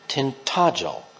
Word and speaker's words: Tintagel: Being American, I likely have no room to say this, but unless I'm mistaken, I believe the common pronunciation for the coastal village in Cornwall is Tintagel.